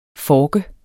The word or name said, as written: FORKE